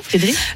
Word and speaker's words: Friedrich: J'espère que je le prononce bien, Friedrich. – Oui, ouais, Friedrich.